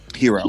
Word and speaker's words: gyro: It's pronounced gyro. – Hero. Yeah, I have one of those.